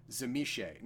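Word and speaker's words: Tzimisce: Now, their lore of Transfiguration makes them the vampire equivalent of the Zemishe. Now, that's the actual pronunciation of this.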